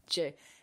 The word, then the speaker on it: Djokovic: think yeah, I think kind of researching for this episode, I came across that he likes to pronounce his family name as Djokovic. – I mean, we don't really say the D, though, do we? It's not like Djokovic. It's just J. Yeah, I guess it's kind of like a silent D, but yeah.